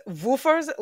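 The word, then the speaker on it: Wüfers: And it's Woofer's, like it's spelt like German styles. I don't know what it is with the Germans in the dog advent calendars, but so far they're 2 for 2 on the list. So Woofer's is spelt W-U with the 2 like dots over top of the U. – Oh, yeah. – F-E-R-S, Woofer's Advent Cookie Calendar.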